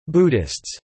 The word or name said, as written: Buddhists